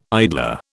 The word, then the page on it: idler: British English